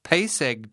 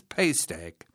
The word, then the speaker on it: Pace Egg: Tid, Mid, Misere, Carlin, Palm, Pace Egg Day, invariably mispronounced as Paste Egg.